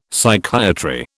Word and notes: Psychiatry: British English

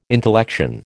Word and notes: intellection: American English

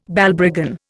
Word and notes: Balbriggan: Indian

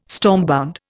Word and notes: stormbound: Indian

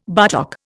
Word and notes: buttock: Indian

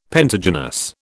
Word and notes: pentagynous: British English

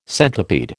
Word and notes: centipede: American English

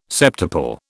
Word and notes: septuple: British English

